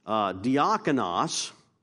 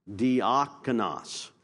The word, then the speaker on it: diakonos: Diakonos is the word in Greek. Diakonos.